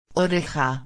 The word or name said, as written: oreja